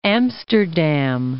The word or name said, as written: Amsterdam